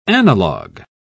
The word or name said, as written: analogue